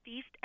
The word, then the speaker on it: Feist: My favorite record out of the year comes from Toronto, Canada, and it's an artist called, I believe it's pronounced Feist, F-E-I-S-T, and the name of the album is Let It Die.